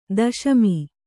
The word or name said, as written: ದಶಮಿ